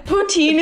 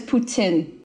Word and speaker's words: poutine: Poutine is even kind of like a butchered way to say it. That's how like the most of Canada we say poutine, but like the correct pronunciation is Putin.